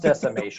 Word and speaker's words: Decimation: Damascus era. Yeah. It's pronounced Decimation.